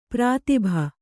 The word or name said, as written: ಪ್ರಾತಿಭ